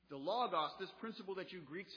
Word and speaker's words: logos: The Greek word behind that, depending on your pronunciation, I pronounce my Omicrons this way, is logos.